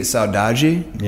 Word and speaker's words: saudade: It would be saudade.